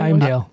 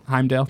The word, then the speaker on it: Heimdall: Idris Elba is Heimdall. Did I say that right? – Heimdall. – Heimdall, thank you.